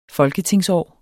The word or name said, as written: folketingsår